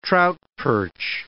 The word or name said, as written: trout-perch